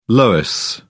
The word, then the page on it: loess: British English